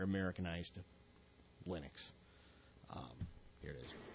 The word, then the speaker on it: Linux: Hello, this is Linus Torvalds, and I pronounce Linux as Linux.